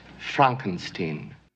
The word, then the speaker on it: Frankenstein: That's Frankenstein. My name, it's pronounced Frankenstein.